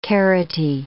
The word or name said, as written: carroty